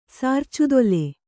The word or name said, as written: ಸಾರ್ಚುದೊಲೆ